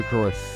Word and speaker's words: Lucroy: Happy 36th birthday to Jonathan Lucroy. Is that how you pronounce it? – Yes, it is. – It is LeCroy.